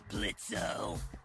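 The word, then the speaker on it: Blitzø: Uh-huh, sure, Blitzo. – The O is silent now, bitch.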